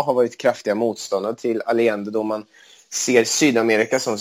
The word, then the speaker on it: Allende: Jag tror Allende är det korrekta spanska uttalet om jag har lärt mig rätt i skolan. – Ja, jag tror att i Sverige säger man Allende. – Okej, dubbel L blir ju J och så vidare.